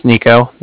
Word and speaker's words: Niko: Like I said, my name's Nico. That's spelled N-I-K-O.